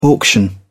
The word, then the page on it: auction: British English